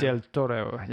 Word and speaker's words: del Toro: It came out by uh, Guillermo del Torre. I've totally Guillermo. Mis- I've I totally mispronounced. I know it's Guillermo got a great name. del Toro.